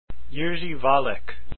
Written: Valek

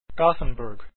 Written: Gothenburg

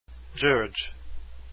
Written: György

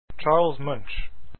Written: Munch

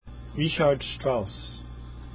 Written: strauss

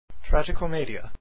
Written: Tragicomedia

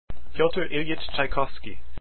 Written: Tchaikovsky

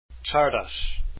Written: Csárdás

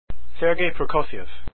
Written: prokofiev

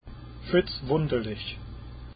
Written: Wunderlich

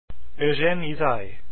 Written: Ysaye

